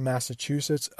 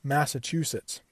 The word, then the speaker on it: Massachusetts: Massachusetts. Think of it like this: Mass, M-A-S-S, just like an A, chew, like you do to your food, C-H-E-W, and then sits, to like sit down, Mass, chew, sits, Massachusetts.